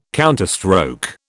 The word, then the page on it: counterstroke: British English